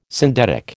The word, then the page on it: syndetic: American English